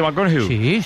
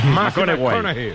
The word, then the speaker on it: McConaughey: Mateo McConaughey. – Sí. – Hay que ir corrigiendo la pronunciación. – McConaughey. Mateo McConaughey. Bueno, pues el Mateo se vamos a poner la música luego.